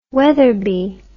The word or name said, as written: Weatherby